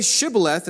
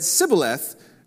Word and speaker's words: Shibboleth: But so they said, "Say Shibboleth," and they said Sibboleth, for they could not pronounce it right.